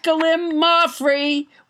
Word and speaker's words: gallimaufry: Let's never forget Gallimaufry, which I pronounced wrong until nobody, James Hyder, corrected me.